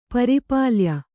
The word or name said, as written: ಪರಿಪಾಲ್ಯ